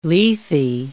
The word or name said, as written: Lethe